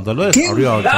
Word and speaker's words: Dolores O'Riordan: Y yo pronuncio como corresponde. No, Dolores O'Riordan.